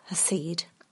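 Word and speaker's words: chesed: This hasid, as it is pronounced in Hebrew, is extraordinary kindness and loyalty, strength of character that Naomi is currently lacking, worn down by life.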